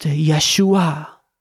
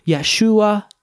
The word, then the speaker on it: Yeshua: So Jesus's name actually means Yahweh is salvation, but pronounced Yeshua. So in Greek, it would be Iesus, and that's the actual name of Jesus in Greek, Iesus. In Hebrew, Yeshua.